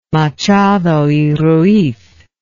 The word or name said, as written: machado y ruiz